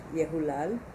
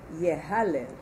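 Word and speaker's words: Yehulal (יהלל): That's how it's read, but the way it's spelled without the vav, as spelled, continue. – As spelled, the word could be pronounced Yehalel.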